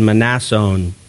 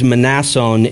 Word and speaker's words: Mnason: And that is the proper pronunciation, Mnason. The M is not silent. I wish it was. That would be a lot easier. But it is Mnason in the Greek.